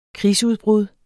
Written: krigsudbrud

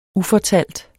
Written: ufortalt

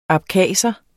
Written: abkhaser

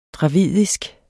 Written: dravidisk

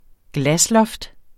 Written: glasloft

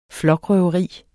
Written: flokrøveri

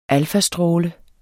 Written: alfastråle

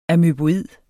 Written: amøboid

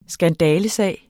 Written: skandalesag

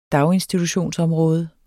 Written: daginstitutionsområde

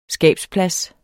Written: skabsplads

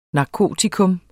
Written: NARKOTIKAS

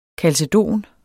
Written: kalcedon

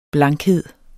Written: blankhed

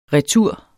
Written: retur

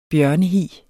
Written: bjørnehi

